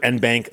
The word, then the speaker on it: en banc: And of course, according to one website I found, the Oxford English Dictionary states the current day British pronunciation is en banc, while the American pronunciation is either en banc or en banc. And I've always heard en banc, so that's the one I go with.